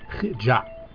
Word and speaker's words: HIja': It looks like a capital H when you see it in print, but it's pronounced like the CH at the end of Bach or the beginning of Hanukkah. The word is HIja.